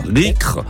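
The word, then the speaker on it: Riec'h: L'île, alors, ça s'écrit R-I-E-C apostrophe H, Rycre. Alors, il faudrait demander aux Bretons si j'ai la bonne prononciation.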